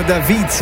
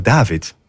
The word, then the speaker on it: David: Damiano David werd altijd gezegd, maar ik heb het even opgezocht. The right pronunciation is Damiano David.